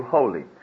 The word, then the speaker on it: wholly: The very God of peace sanctify you wholly. Notice how the word holy is spelt. It isn't H-O-L-Y, holy, as in church. It's W-H-O-L-L-Y, thank you.